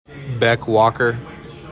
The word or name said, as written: BECK WALKER